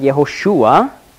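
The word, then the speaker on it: Jehoshua (יהושע): Die griechische Form des Namens Josua, hebräisch ausgesprochen Jehoshua, oder Kurzform Yeshua, ist Jesus.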